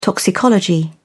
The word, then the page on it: toxicology: British English